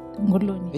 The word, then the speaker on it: Nguluni: so Uh, no, the Ngoloni. – Nguluni. – Eh, Say it like a Kamba kabisa. – That's it. Nguluni.